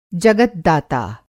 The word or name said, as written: जगद्धाता